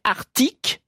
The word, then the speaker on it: Arctique: j'ai toujours prononcé Arctique et Antarctique sans le C.